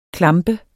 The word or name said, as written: klamp